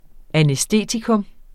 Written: anæstetikum

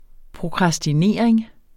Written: prokrastinering

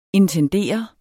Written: intendere

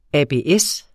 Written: ABS

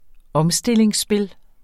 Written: omstillingsspil

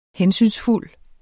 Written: hensynsfuld

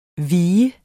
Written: vigende

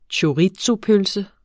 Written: chorizopølse

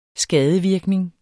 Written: skadevirkning

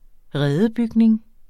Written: redebygning

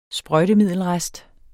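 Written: SPRØJTEMIDDELRESTER

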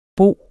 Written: bor

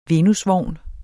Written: VENUSVOGNE

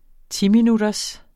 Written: timinutters

